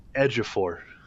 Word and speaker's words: Ejiofor: I look it up as you were talking, and it's Ejiofor. That's how you say his name, which I would have never guessed based on the spelling.